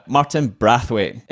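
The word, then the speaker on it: Martin Braithwaite: Martin Brathwaite. Explain to me, that's how you say his name, right? – Yes, it is. Yeah, he has told it himself.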